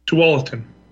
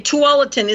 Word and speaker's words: Tualatin: Again, how do you pronounce that city? – Tualatin. – Okay, Tualatin.